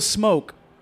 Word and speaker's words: Cmoke: So, first one, Lil Smoke. – Yeah. – That's spelled C-M-O-K-E. – I bet you I know what he sounds like already. – C-M-O-K-E.